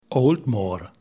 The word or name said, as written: Aultmore